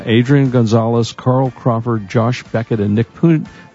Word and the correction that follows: Punto: I guess Punto is pronounced,